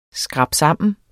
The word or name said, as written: skrabsammen